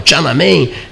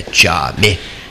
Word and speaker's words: Tiananmen: digo, mas afinal, Tiananmen, ele ria de mim e dizia assim: Tiananmen. Tiame. Em mandarim, a pronúncia correta é Tiame.